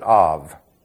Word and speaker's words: Ab: In the Old Testament, it was called Ab. That's simple, isn't it? A-B. Or if you pronounce it, Av.